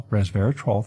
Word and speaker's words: resveratrol: But according to this study by researchers at Pennsylvania State University, what we call Penn State, the combination of resveratrol, resveratrol, 3 syllables, from the skin of red grapes, that's where you're getting the phenolic compounds, it's actually in the skin.